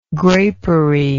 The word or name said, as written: grapery